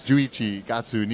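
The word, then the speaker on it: Juichi gatsu (十一月): Juichi getsu ni. No, Juichi gatsu ni Tokyo ni.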